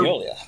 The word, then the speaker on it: Mignola: Started, Mignola.